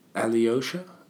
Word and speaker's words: Alyosha: Today we're going to be discussing the short story by Leo Tolstoy called Alyosha. How would you say that? Alyosha? – Yeah. – A-L-Y-O-S-H-A.